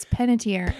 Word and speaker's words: Panettiere: I think it's Panettiere.